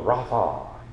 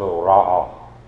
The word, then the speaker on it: Rapha: And then we saw Jehovah Rapha, or some pronounce that Jehovah Ra'a, the God who heals.